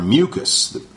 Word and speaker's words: mucous: They are mucus. I think I talked about this word before. There's two different words that are pronounced exactly the same. Mucus, M-U-C-O-U-S, and mucus, M-U-C-U-S.